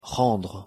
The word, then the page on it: rendre: French